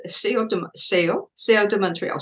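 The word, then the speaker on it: Céu de Montreal: And it's Seo de Montreal. Seo means sky.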